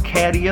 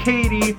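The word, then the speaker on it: Cady: It's not caddy, it's pronounced Katie.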